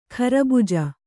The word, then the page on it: ಖರಬುಜ: kharabuja